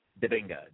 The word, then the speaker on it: Dibinga: If not, it's S-A-I-D. Middle initial is K-A-K-E-S-E. Dibinga, D-I-B-I-N-G-A.